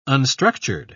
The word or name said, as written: unstructured